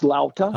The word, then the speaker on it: lauta: Now, is a luta tuned like a regular guitar? – It's pronounced lauta. – Oh, lauta. I'm sorry.